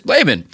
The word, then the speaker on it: Laban: My name is Laban. How would you pronounce it? Actual pronunciation is Laban. And it's L-A-B-A-N.